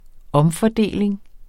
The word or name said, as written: omfordeling